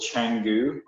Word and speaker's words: Canggu: It's called Canggu, which is spelled C-A-N-G-G-U.